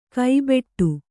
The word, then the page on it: ಕೈಬೆಟ್ಟು: kannada